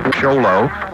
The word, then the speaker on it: Show Low: Write CAJI, C-A-J-I, Box Sholo, that's spelled S-H-O-W-L-O-W, Arizona 85901.